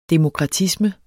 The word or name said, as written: demokratisme